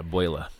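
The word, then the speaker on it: Beulah: I mean, Buela, how do you say that? – Yeah, Buela.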